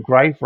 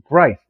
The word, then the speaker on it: Greif: And just so you feel better, Greif, I always tell people Greif rhymes with life. And I was being introduced and there was a couple of us and I said, Greif rhymes with life. And he gets up there and he announces, Martin Greif. And the guy next to me goes, must rhyme with leaf.